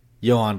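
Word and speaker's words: Jhoan: Is it Johan? – Johan Duran, yeah.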